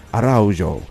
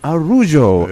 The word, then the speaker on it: Araujo: I thought, looking at the spelling, maybe Araujo. Araujo? No. I've been told. I've been corrected. The correct pronunciation, Araujo attorneys.